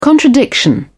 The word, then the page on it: contradiction: British English